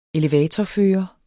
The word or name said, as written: elevatorfører